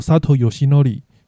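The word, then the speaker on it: 佐藤義則: 他也因为游龟当时加入养乐多队的时候，那火腿队那时候的投手教练佐藤义则，他的日文读音也是叫 Sato Yoshinori，所以最后，那养乐多最后是决定以游龟，也就是 Yoshinori，当做佐藤游龟他的一军的登录名称。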